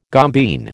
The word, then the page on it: gombeen: American English